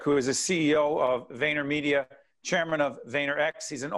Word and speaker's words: VaynerMedia: Yes, VaynerMedia.